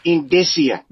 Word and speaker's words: indicia: Now, it's pronounced indicia. – Indicia.